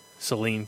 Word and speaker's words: Saline: Saline County, like Celine That's Dion. Right. Right, it's pronounced like Celine Dion, but spelled like the stuff that you squirt up your nose in the winter when you get too many uh, bloody noses, or maybe that's just me.